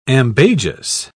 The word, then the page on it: ambagious: British English